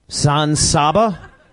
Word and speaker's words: San Saba: He's not in San Sabo? – San Saba? No. – Oh, Alec Baldwin not wrong in San Saba. For a whole week now, I've been pronouncing it wrong.